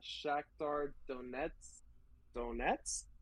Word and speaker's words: Shakhtar Donetsk: And then for third, Europa League, I got Shakhtar Donetsk. – You know, you said it right. – I want to say, yeah, Shakhtar Donetsk.